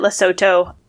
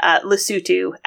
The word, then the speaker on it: Lesotho: and he responded, he said he pronounced it Lesotho, which, is a fine pronunciation if you've just read it. Lesotho, Alex corrects him, is the correct pronunciation.